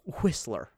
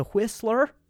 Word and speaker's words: Whistler: And we went up to a place called Whistler, okay? And I'm not trying to emphasize the H; it is spelled that way. So we went up to Whistler for Valentine's Day, and it was just lovely.